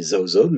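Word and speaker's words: Jaojoby: Celui que nous venons d'écouter s'appelle Zaozob. Oui, Zaozob, mais son nom s'écrit avec un J. Le J se prononce Z en malgache.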